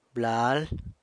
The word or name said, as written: blal